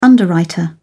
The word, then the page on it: underwriter: British English